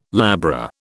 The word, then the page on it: labra: British English